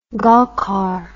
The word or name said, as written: golkar